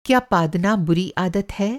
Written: पादना

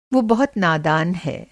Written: नादान

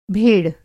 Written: भेड़

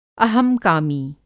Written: अहंकामी

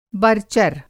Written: बरचर

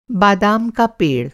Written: बादाम का पेड़

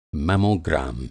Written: मेमोग्राम

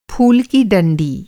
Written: डंडी

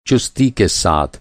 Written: चुस्ती

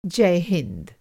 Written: जय

